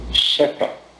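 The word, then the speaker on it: Schäffer: Schaffer. Acontece que esse A tem um trema em alemão, então é Schaffer, né? Se escreve S-C-H-A, né? F-F-E-R, com um trema no A. Em alemão se pronuncia Schäfer.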